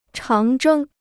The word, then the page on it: 长征: cháng zhēng